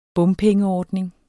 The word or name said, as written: bompengeordning